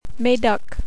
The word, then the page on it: Medoc: French